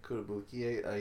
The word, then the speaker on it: Kotobukiya: Or is, who from the League of Geeks called him Kotobukiya? – Kotobukiya, yeah.